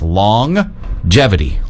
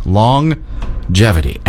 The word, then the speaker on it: longevity: You pronounce the G twice. Longevity. Longevity.